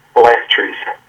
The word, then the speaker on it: phylacteries: Phylacteries. Like factory.